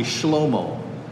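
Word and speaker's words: Shlomo (שלמה): In fact, the Hebrew pronunciation of Solomon is actually Shlomo.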